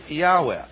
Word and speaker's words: Yahweh: And probably best, put an A in there, YAH, Yah, and then Weh, put an E in here, and pronounce it Yahweh.